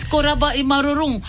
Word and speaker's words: korabwa i marurung: And I'm going to reply, korabwa I marurung. – Oh, am I saying it right? – Yes, korabwa I marurung. Fine, thank you.